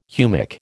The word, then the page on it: humic: American English